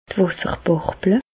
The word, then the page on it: d' Wàsserbàrble: Bas Rhin; Reichshoffen